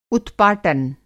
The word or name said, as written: उत्पाटन